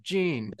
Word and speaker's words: Jean: and John, Jean. I pronounce it like the French version. Jean.